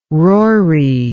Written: rory

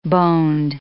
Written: boned

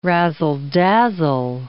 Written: razzle-dazzle

